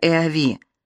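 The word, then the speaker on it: Eawy: Обездоленная семья переехала в маленькую коммуну Сен-Санс, департамент Сен-Маритим, близ государственного леса Эави. Так и произносится Э-А-Ви, где Дрейфус Луи Фердинанд стал лесничим.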